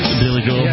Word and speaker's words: Billy Joel: Is that Jilly Bowl? – Yes. – Billy Gold. – Yes. – Sorry, yes. I got the first syllable of each word inverted there.